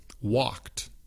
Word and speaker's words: walked: Por ejemplo, walked, talked. Tiene la ed al final, pero es una sola sílaba.